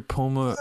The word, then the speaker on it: Pomerantsev: Peter Pomerantsev.